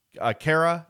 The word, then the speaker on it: Khaira: So, Khaira is the right pronunciation.